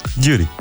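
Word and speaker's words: Gyuri: Îmi place că pronunți Giuri cum nu pronunță nimeni. – Giuri. – Giuri. – Deci, parte... cum nu-l pronunță nimeni, gen corect. – Păi da, da, știu, adică îi sună ciudat când îl zici așa maghiarizat. – Așa corect în limba lui.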